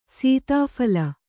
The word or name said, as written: ಸೀತಾಫಲ